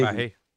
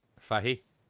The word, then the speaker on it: Fahey: Well, I can definitely pronounce Jasper Fahey. – Fahey, you mean? – General Kerrigan. – Fahey. – Hmm? – F-A-H-E-Y? – Fahey. – Fahey? – Yeah. Fahey.